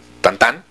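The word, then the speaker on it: Tintin: Pues, por ejemplo, Tintín, que ahora sé que se llama Tantán, o sea, que así se pronuncia, fíjate.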